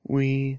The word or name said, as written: wi